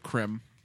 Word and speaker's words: creme: Oh, cream. – Creme. – Yeah. There's an E at the end of it.